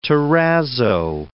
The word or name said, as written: terrazzo